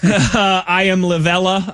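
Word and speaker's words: Iamaleava: I am Lavella, I believe is how he pronounces his last name, out of UCLA.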